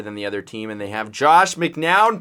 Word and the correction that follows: McCown: playing quarterback. McCown, not McNown. I don't even know how to say his name.